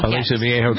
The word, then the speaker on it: Aliso Viejo: Aliso Viejo, California.